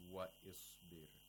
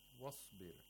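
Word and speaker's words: Wasbir (واصبر): We don't say wa isbir. Wasbir.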